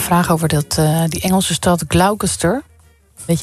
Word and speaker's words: Gloucester: De Engelse plaatsnaam Gloucester spreek je uit als Gloucester. – Gloucester. Ja. Gloucester.